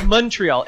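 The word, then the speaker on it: Montréal: It's Montreal. M-U-N.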